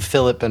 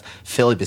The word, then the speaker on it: Filip: You'll also find our podcast, the Philip and Frederick podcast. Philip is spelled F-I-L-I-P. I think I need to do some international version of my name here, or at least the spelling. The Philip and Frederick podcast, you can find it on iTunes and everywhere else.